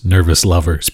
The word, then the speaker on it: NRVSLVRS: That was the track Far Away from Nervous Lovers, and that's spelled NRVSLVRS, Nervous Lovers, because like Big Building, they seem to have something against vowels, but they're really awesome nonetheless.